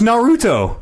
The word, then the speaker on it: Naruto: I haven't even watched Naruto. And I just say it like I'm Japanese, I guess. I don't know. Put the emphasis on the middle syllable.